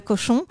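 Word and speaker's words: Cauchon: C'est C-H-A-U-N-O, voilà, Cochon.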